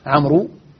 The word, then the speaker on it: عمرو: وهذه الواو فقط للتفريق بين عمرو وعمر، ولا تنطق ولا علاقة لها بأصل الكلمة وحروفها.